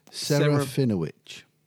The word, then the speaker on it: Serafinowicz: Now, how are we pronouncing Peter's surname? – Serafinowicz. He's in The Tick. – Yes, that's right.